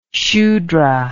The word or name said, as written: shudra